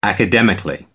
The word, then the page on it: academically: British English